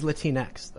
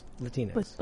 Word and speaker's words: Latinx: Latinx. – Like Kleenex, yeah. – Yeah, like Kleenex. – Is that how it's pronounced? Latinx or is it Latinx? Yeah, I don't know. – Like Kleenex. – Latinx? – Yeah.